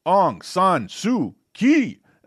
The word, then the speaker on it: Aung San Suu Kyi: Nobel Peace Prize is awarded to Aung San Suu Kyi.